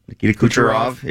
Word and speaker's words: Nikita Kucherov: Nikita Kucherov. Is that how you say it? – Nikita Kucherov. Yeah.